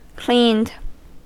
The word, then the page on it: cleaned: American English